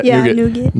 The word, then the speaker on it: nougat: Snickers has nougat. So I'm saying that right? – Yeah, nougat.